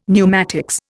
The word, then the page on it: pneumatics: Indian